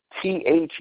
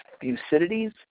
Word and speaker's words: Thucydides: And this comes from a general, an ancient Greek general named Thucydides, I believe is the way you pronounce his name. It's Thucydides, Thucydides.